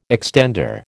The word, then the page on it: extender: American English